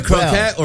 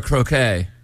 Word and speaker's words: croquette: Is it a croquette or a croquet? – I've always heard croquet. – At fancy places, we're supposed to not pronounce the last. – No, croquet is what you play. Croquette is what you eat.